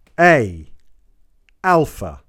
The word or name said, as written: Alpha